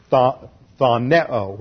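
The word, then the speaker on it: Thoneo (φθονέω): The second verb that's used here is the Greek word thoneo, which is a little hard to pronounce in the English. Looks like this. P-H-T-H-O-N-E-O. Thoneo.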